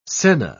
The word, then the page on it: senna: British English